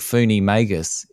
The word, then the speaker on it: Foonie Magus: And the studio is called Funi Magus. Is that how you say it? – Yes.